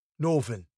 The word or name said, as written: northern